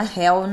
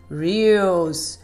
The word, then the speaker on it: Reels: Gente, não é Hell nem Hells, nem Reels. Não é isso, hein, gente? Vamos pronunciar direitinho com a titia. Vamos lá, repetindo. Reels.